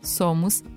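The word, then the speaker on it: Sommos: Ah, Somos se escreve com dois M's, tá?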